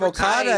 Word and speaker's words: Okada: Another favorite of mine, personally, the Rainmaker, making sure I'm getting his name right, Kazuchika Okada. – Yep, Okada at number two, the Rainmaker himself.